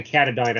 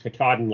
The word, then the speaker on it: Katadyn: A large one for at home, like a big Berkey, and then a compact one like Katahdin filter. That's spelled Katadyn. Some people say Katahdin. I think it's actually pronounced Katahdin, like Mount Katahdin.